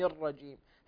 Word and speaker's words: الرجيم: حسبك بارك الله فيك يا ابني يوسف، انتبه بارك الله فيك إلى أولا في الاستعاذة، انتبه إلى تفخيم الرجيم أعوذ بالله من الشيطان الرجيم، هكذا تنطق،